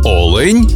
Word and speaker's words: олень: в однині, і в множині наголос падає на перший склад – олень та олені.